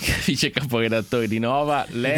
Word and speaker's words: Nova: Allora, poi una cosa d'orgoglio, mentre noi Nova lo pronunciamo Nova. Senza, anche se c'ha l'accento.